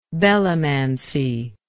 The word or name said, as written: belomancy